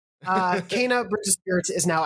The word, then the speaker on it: Kena: But yeah, of course, we're going to be talking about, again, to get that vocal SEO, depending on how you're pronouncing it, Kena, Kena, or Kena. I believe it's Kena, Bridge of Spirits, which we'll jump into.